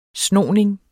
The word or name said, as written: snoning